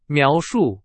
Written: 描述

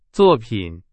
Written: 作品

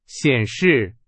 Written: 显示

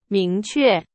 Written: 明确

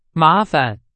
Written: 麻烦

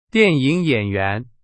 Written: 演员

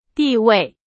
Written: 地位

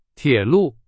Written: 铁路

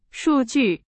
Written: 数据

